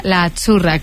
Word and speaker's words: Azzurra: Es que Azzurra. Sí, como porque pizza, la Z no es la Z como decimos en catalán tan sonora, no. Es Azzurra. – Muy bonito, muy bonito. – Es como pizza.